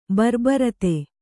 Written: ಬರ್ಬರತೆ

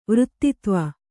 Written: ವೃತ್ತಿತ್ವ

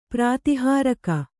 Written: ಪ್ರತಿಹಾರಕ

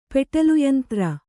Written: ಪೆಟಲುಯಂತ್ರ

